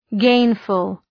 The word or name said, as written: gainful